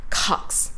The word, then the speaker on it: Cox: こちら、コックスではなく、 cox。 – cox proportion。 – cox p r シ p ン r o n って言ってますよね。 cox。